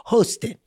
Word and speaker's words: Hostin: Is it Hosted or Hostin? And she was like, well, you can just say it however you want. It's my husband's last name. Just go. And then she cracked the code and said, look, he's half Spanish, half Haitian. It's really pronounced Hosted.